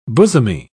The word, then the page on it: bosomy: British English